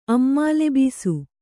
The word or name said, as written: ಅಮ್ಮಾಲೆಬೀಸು